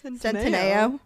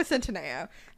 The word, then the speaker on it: Centineo: Centineo. – I say it wrong every time. Noah Centineo.